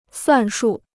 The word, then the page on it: 算数: suàn shù